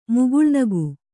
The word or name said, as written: ಮುಗುಳ್ನಗು